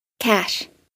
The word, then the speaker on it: cache: At 18:32, something that bothered me all week long, inability to say the word C-A-C-H-E during paid ads. – Gunslinger's cache. – C-A-C-H-E is pronounced like this. – Cash. – Cash. – C-A-C-H-E-T, on the other hand, is pronounced like this.